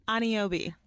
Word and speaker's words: Aniobi: Aniobi.